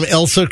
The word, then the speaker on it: Elsa: I think I'm pronouncing Elsa right. Am I pronouncing Tropical Storm Elsa correctly? – Yeah, right on.